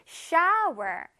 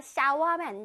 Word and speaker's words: shower: 끝에 R 발음을 꼭 살려줘야 돼. Shower. Shower. 할 수 있겠어요? 해보자. 시작. Shower. 세 번 해볼게요. 시작. Shower, shower, shower. 잘했습니다. 샤워하면 안 돼.